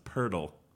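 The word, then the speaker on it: Poeltl: No offense, but that's a hard name to pronounce. It's Pirtle. – Pirtle? – Yeah. – Jakob Pirtle out of Utah.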